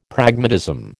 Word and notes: Pragmatism: American English

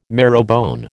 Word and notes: marrowbone: American English

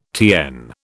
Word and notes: TN: British English

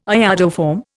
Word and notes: iodoform: Indian

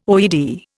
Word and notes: OED: Indian

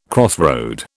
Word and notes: crossroad: British English

